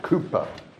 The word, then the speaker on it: Cowper: lovely hymn of William Cowper, or Cooper, as he pronounced it.